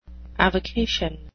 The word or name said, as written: avocation